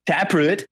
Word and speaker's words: Taproot: Taprit. – Taprit. – Mm-hmm. – Are you? Do you not know how to say the band name Taproot? Taproot.